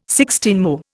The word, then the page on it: sixteenmo: Indian